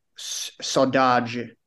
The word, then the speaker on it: saudade: It's a Portuguese word, saudade, and it's spelled S-A-U-D-A-D-E.